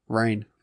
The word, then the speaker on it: reign: How do you pronounce R-E-I-G-N? – Rain. – There's no A in it, but the E-I makes an A sound. – B-E-I-H-N is Bean.